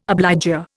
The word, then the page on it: obligor: Indian